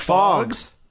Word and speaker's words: Phogs: It's called Fogs. – P-H-O-G-S. – Spell that for me one more time. – P-H-O-G-S. – Okay. Fogs.